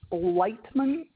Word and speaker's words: Lightman: And my last name is Lightman, but it's spelled funny.